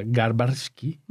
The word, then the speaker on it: Garbarski: Es Sam Garbinski, a ver cómo lo se pronuncia, Garbarski.